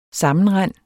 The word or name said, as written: sammenrend